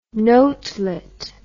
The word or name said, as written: notelet